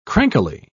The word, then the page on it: crankily: British English